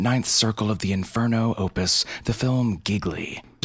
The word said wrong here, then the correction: Gigli: Gigli, like, rhymes with really.